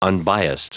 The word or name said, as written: unbiased